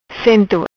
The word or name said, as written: Cento